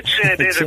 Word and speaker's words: Cenedese: Io sono Alessandro Cenedese, pronuncio male il cognome, ma comunque è Cenedese con la C di cane o di Como.